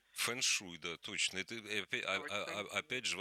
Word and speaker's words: фэншуй: А по-китайски фэн – это ветер. Шуэй – вода, шуэй. – Шуэй, ага. Поэтому фэншуй даже вообще почти не меняется произношение.